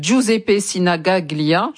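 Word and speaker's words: Giuseppe Sinigaglia: C'est le stade Giuseppe Sinagaglia. J'espère que j'ai bien prononcé, Matteo. – C'est bien, Matteo. – C'est bien, c'est très bien.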